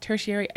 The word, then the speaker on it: tertiary: That, for the record, word is pronounced tertiary. I did hear someone the other day call it the territory audience.